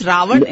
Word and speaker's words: Ravan: You know, it's pronounced different ways in different parts of the country, and yes, Ravan is the.